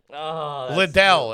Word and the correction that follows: Lidl: I think it's called, or something Lidl. – Like that. – I think it's Lidl. I think it's pronounced Lidl.